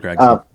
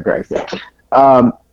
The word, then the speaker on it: Gragson: How do you say it? Gragson, right? – Yes, I believe Gragson. – Gragson.